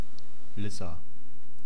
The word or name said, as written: lissa